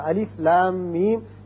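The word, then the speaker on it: الم: الف لام میم، یعنی لا کو جب کھینچیں گے مد کریں گے 6 حرکات، پھر میم مشدد ہے اس پر غنہ کریں گے، پھر میم جب پڑھیں گے اس پر بھی مد کریں گے 6 حرکات، اس کو کھینچیں گے.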